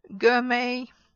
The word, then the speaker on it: gourmet: But words like ballet, gourmet or chalet, ballet, gourmet or chalet, you don't pronounce them at all. So it's just an A sound, like holiday, but in writing it's ET, and you don't pronounce the T there. So some words with S-T-E-N, like listen, we're doing listening exercises, you're listening to a podcast, you're not listening to a podcast.